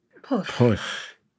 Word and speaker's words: Pwll: Poof. – Is that any good? – There's no L. You've got to forget that there's ever poof. An L. – Poof.